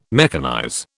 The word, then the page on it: mechanize: British English